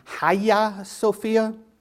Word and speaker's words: Hagia Sophia: Well, after they raided the Hagia Sophia, which I've been told is the correct pronunciation, is something along the lines of Hagia Sophia, which is the big church there in Constantinople, which is now called Istanbul.